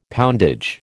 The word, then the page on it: poundage: American English